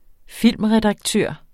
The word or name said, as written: filmredaktør